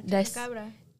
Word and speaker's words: Chupacabra: El Chupacabra? – Yeah, Chupacabra.